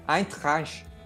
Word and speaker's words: Eintracht: Eintracht. Aí, ó. Que bela é, pronúncia.